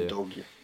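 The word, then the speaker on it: Udogie: Eh, och på andra då är det ju Destiny Udogi, eller hur man uttalar det i... – Udogi, just Ja, det. precis, just i Udinese det.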